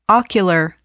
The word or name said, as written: ocular